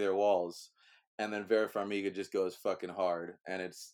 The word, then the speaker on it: Farmiga: Vera Farmiga, yep.